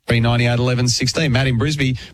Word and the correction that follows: Brisbane: Brisbane even.